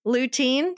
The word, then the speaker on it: lutein: We need some lutein. Did I say it right this time? – That's correct.